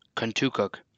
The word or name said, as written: Contoocook